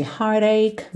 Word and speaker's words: heartache: Repara também que esses r's finais aí de heartache, mistake não são pronunciados, tá? O último som é k, k.